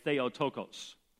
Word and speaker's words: Theotokos: Now, modern Greek speakers would say Theotokos, but I was taught what's called Erasmian pronunciation, and Erasmian pronunciation actually allows you to figure out one word from another word, and it differentiates the pronunciation of the vowels.